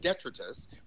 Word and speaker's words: detritus: D-E-T-R-I-T-U-S. You've probably read it, and you might have thought it was pronounced detritus, which is what I thought it was pronounced for a very long time. but it's pronounced detritus.